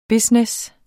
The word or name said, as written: business